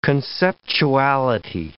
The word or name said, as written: conceptuality